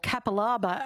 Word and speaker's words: Capalaba: We've got Karen from Capalaba on the line, or Capalaba, as it's known. Hi, Karen. That tickled Karen, that one. Capalaba. – Well, I'm not a honey. – Capalaba honey.